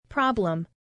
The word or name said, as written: problem